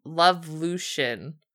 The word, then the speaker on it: Lovelution: It's called Lovelution. – Okay. – I think that's how you say it. It's evolution with love instead of just, you know.